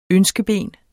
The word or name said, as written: ønskeben